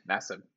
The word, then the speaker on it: Nassib: Nassib.